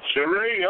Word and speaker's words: Cherri: Cherri, okay.